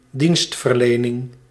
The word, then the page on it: dienstverlening: /di:nst.vǝɾ.le.nɪŋ/